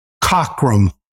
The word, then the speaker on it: Cockram: And so the reason say his name again, Steve Cochran? – Cockrum, C-O-C-K-R-A-M.